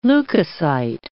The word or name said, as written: leukocyte